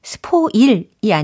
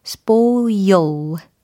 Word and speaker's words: spoil: 어, 발음이 spoil이 아니고 spoil, spoil. 끝부분에 l, 이런 L 사운드를 살려주시면 훨씬 더 멋진 발음이 되겠죠.